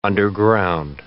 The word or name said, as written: underground